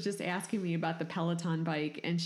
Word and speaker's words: Peloton: Pendleton bike that's $3,000 and tell you how it works. Unless Pendleton. – Are you talking Peloton? – Peloton, whatever. – You got to say it right, man. Peloton.